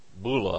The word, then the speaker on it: bulla: Rob Sullivan told us of the bulla, I believe is the pronunciation, with the name and inscription of the prophet Isaiah that had been found.